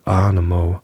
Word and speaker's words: Ahnamoe: wenn er über die Furt von Anamo, Anamo, irgendwas mit Mo, Mo, ich glaube, die meinen dich. Äh, A-H-N-A-M-O-E. Wie spricht man das aus? Äh, Arnemo.